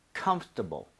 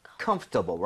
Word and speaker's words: comfortable: 뭐냐면 comfortable, comfortable. 뭐냐면 여러분들 중간에 OR 보이죠? – 네. – 이거를 발음을 거의 안 하는 사람도 엄청 많습니다. 그래서 그냥 comfortable, right?